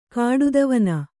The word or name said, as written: ಕಾಡುದವನ